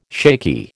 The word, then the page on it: shaky: American English